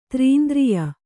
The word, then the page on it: ತ್ರೀಂದ್ರಿಯ: trīndriya